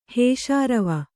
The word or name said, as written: ಹೇಷಾರವ